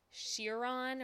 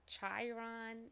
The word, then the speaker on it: Chiron: I don't know how to pronounce it, but it's going to be called the Bugatti. Bugatti Chiron or Chiron. It's C-H-I-R-O-N. It already exists. – Chiron.